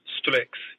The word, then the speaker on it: Strix: Strix? – The monstrous Strix, yeah.